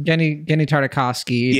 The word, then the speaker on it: Tartakovsky: Well, the third one was the one that was most in the vein of like Getty Tartakovsky. Is that how you pronounce his name? – Getty Tartakovsky, yeah.